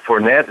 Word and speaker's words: Fournette: That's just my opinion, because you got Fournette.